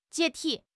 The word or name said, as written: 解体